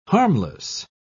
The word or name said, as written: harmless